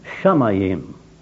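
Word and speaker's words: Shamayim (שמים): Ich habe das Wort Erde, hebräisch gelesen, ausgesprochen Eretz und Himmel Shamayim. Eigenartig, unsere Erde ist ja nur, oder nur, ja, wie wir wollen, nur und doch, erstens, einer der zwei Brennpunkte im ganzen Weltall.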